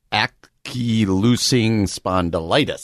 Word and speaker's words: ankylosing spondylitis: Ankylosing spondylitis.